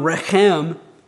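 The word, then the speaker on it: rechem (רֶחֶם): The Hebrew noun rechem means womb or matrix, that reproductive organ that's unique to females. The verb form of this noun is racham. The difference in spelling and pronunciation is due to vowel differences only. It's the same Hebrew consonants. We're just trading, if you remember from Hebrew class, you're just trading the A-class vowels or E-class vowels for A-class vowels.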